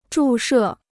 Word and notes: 注射: zhù shè

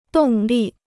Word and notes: 动力: dòng lì

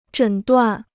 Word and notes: 诊断: zhěn duàn

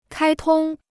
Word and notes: 开通: kāi tong

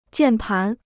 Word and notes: 键盘: jiàn pán

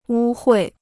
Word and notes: 污秽: wū huì

